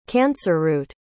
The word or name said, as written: cancerroot